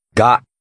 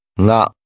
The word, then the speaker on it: が: Ga. Ga. – You will hear this letter with both a hard G sound and with another more nasalized sound.